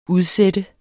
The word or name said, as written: udsat